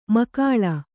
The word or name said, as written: ಮಖಾಳ